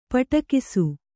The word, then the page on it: ಪಿಡಿಕೈಸು: kannada